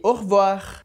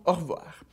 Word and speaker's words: au revoir: Então essas três palavras que servem de exemplo pro R pronunciado no final de palavras, de novo, bonjour, amour e au revoir. Fechou? Bonjour, amour, au revoir.